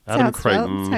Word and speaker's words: Creighton: We also have Adam Creighton, I believe it's pronounced. – That's right. – That's how it's spelled. – Adam Creighton. That's how you say it.